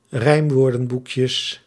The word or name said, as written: rijmwoordenboekjes